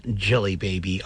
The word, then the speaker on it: Djelibeybi: that is called D-J-E-L-I-B-E-Y-B-I. It's pronounced Jelly Baby.